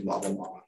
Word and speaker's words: LABA-LAMA: And if they get worse, do LABA-LAMA. Did I say that right? – You did.